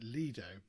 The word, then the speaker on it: Lido: And I said, well, I always pronounce it Lido because that's how Boz Scaggs pronounces it in Lido Shuffle.